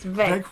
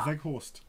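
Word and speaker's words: Weghorst: Yeah, it's V. You don't pronounce the W. It's Veghorst. You are Veghorst.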